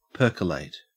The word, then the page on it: percolate: Australian English